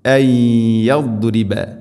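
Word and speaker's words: അയ്യദ്രിബ (أَنْ يَضْرِبَ): ശേഷം അയ്യദ്രിബ, സാക്കിനായ നൂന് ശേഷം യാ ഇദ്ഗാം ബിഗുന്ന, നൂനിനെ യാഇലേക്ക് കൂട്ടിലയിപ്പിച്ച് മണിച്ചുകൊണ്ട് ഉച്ചരിക്കണം. അയ്യദ്രിബ അയ്യദ്രിബ അയ്യദ്രിബ.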